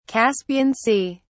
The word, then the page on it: Caspian Sea: British English